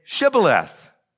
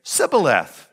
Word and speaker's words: Shibboleth: And when they said no, they said to him, then say Shibboleth. And he would say Sibboleth, for he could not pronounce it right.